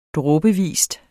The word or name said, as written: dråbevis